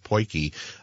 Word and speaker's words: potjie: We heard yesterday from a family in Hinesburg that use an African poiki, and that's spelled P-O-T-J-I-E, pronounced poiki, pot to make the most delicious turkey.